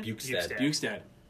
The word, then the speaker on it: Bjugstad: Bjugstad. Is that how you say his name? – Bjugstad.